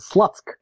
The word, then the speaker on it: Slutsk: But if you spell it in English, it's pronounced as Slutsk.